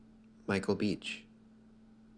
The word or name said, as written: Beach Michael